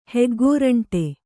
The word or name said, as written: ಹೆಗ್ಗೋರಂಟೆ